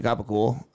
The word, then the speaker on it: capicola: Like the gabagool, it's like, spelled out like capicola, I'm pretty sure. – Yeah, okay. It's like, capicola. They just pronounce the C's as fucking soft G's.